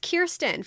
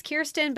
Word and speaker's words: Kirsten: It is pronounced Kirsten. For those who want to know, I have looked it up. I've heard him say it. It is Kirsten, but spelled Kirsten.